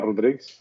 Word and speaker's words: Rodrigues: Yes, so my name is Joel Rodriguez. I'm Portuguese. That's how you pronounce it correctly.